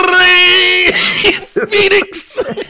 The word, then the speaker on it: Rey Fénix: Yes, you got to say it like Justin Roberts. – Oh, I Rey Fénix. – I can roll my R's, but not that hard, man.